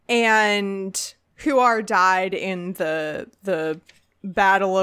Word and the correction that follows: Huor: I do believe it is pronounced Huor and Tuor.